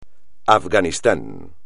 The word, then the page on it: Afganistán: espagnol